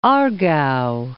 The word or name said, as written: Aargau